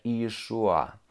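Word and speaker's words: Иешуа (ישוע): И произносится на языке оригинале как Иешуа.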